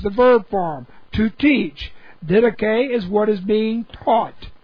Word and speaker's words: didache: Both of them are correct translations of the Greek didache, D-I-D-A-C-H-E, long E, didache.